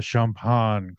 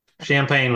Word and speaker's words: Champaign: Question number one, is it okay if we refer to it as the Champaaaane Clippers? Champaaaaane. – Yeah, there's a lot of fun names for this area, in the local vernacular, but yeah. Champaign would be the correct way to pronounce it.